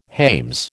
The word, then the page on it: hames: American English